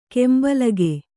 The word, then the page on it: ಕೆಂಬಲಗೆ: kembalage; kannada